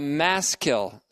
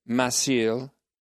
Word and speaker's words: Maschil: a maskil psalm, M-A-S-C-H-I-L, meaning that it is intended to instruct, maskil, or some pronounce it masil, means to instruct, comes from a word meaning to instruct, to impart understanding, and to promote piety and wisdom.